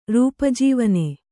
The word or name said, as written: ರೂಪಜೀವನೆ